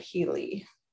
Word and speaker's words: Healy: E-V-A-N? – Evan, E-V-A-N, H-E-A-L-Y, Healy. Okay. Yeah, Evan Healy.